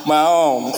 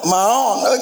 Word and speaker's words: Maon: How do you pronounce it? How is the pronunciation? – Maon. – Maon, okay.